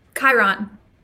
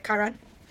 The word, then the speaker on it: Chiron: How do you pronounce this, out of curiosity? – Chiron. – Okay, that's what I thought. Okay, Chiron.